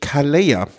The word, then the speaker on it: Calella: Die Vuelta führte durch Calella. Das doppelte Ech spricht man im Spanischen und Katalanischen plattgesprochen als J aus.